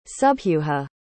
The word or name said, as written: subhuja